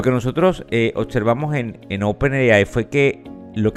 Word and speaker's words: OpenAI: compañía OpenAI, es así como se pronuncia en inglés, que es a su vez quien inventó la herramienta que muchos quizás de ustedes también utilizan, que se llama ChatGPT.